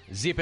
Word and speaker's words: Zyppah: Zipa is spelled Z-Y-P-P-A-H. That's Happy Z spelled backwards.